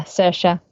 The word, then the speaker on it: Saoirse: Yeah, Saoirse.